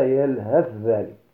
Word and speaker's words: يلهث ذلك: الثاء مع الذال، وذلك في يلهث ذلك، فتنطق يلهث ذلك بعدم نطق الثاء والاكتفاء بتشديد الذال، فتنطق هكذا يلهث ذلك.